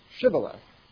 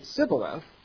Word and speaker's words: Shibboleth: If he said, "No," then they would say to him, "Then say Shibboleth." And he could not say, and he would say Sibboleth, for he could not pronounce it right.